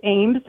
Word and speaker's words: Ambs: Number two is Todd, I think it's pronounced Ames, A-M-B-S.